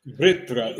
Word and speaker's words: Vṛtra: Vṛtra. It's a V, and it has a little R attached, and then Tra, which is a compound consonant.